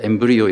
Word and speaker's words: embryo: 영어로는 어, embryo. 예, 제 딸이 발음 교정을 해줬습니다. 제가 embryo 이랬더니 아 embryo 이렇게 하시던데 그 단어입니다.